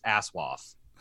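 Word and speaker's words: ASOIAF: Which Kim let me know is the proper pronunciation of that is ASWAF.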